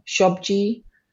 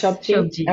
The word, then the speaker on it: sabji: where the rest of you would have roti shobji. Am I saying that right? – Yes, sabji. Okay. – That's correct.